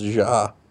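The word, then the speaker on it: Jai: I call it J. I don't care. – Apparently, he was getting pissy. It's pronounced Ja.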